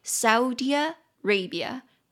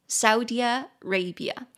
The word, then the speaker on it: Saudi Arabia: 所以它其实那个乌的音是很快的，很快就咻一下就过去的，所以沙烏地阿拉伯的英文念作 Saudi Arabia, Saudi Arabia, 它其实比较像是 Saudi Arabia 再加上 Arabia 这样子念，所以 Saudi Arabia。